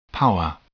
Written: power

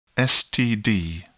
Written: STD

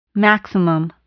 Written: maximum

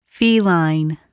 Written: feline

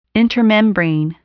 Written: intermembrane

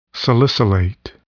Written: salicylate

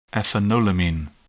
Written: ethanolamine

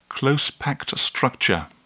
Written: close-packed structure

